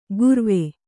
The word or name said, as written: ಗುರ್ವೆ